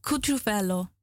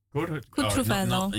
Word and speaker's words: Cutrufello: Yeah, how do you pronounce this? Cutrufello? – Cutrufello. Cutrufello. – Yeah, but L. – Right, yeah, it's Italian.